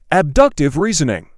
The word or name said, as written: abductive reasoning